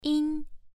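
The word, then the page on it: yin: tone 1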